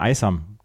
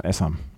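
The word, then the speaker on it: Aisam: wie wird denn der Vorname ausgesprochen, weil ich habe mir immer Aysam gesagt und er sagte Aysam. Und seitdem sage ich dann auch Esam.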